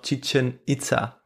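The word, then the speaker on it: Chichén Itzá: Ja, du hättest noch zur Auswahl äh, Tikal und Chichen Itza. – Ich nehme noch Chichen Itza.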